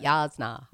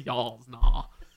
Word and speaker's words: Jasnah: I said it right, Jasnah. – Jasnah. yeah, you got to get it back in the back of your mouth.